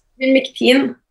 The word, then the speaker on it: Ivermectin: Kan du bare fortelle litt om Ivermectin, er det sånn det uttales? – Ivermectin. Ivermectin.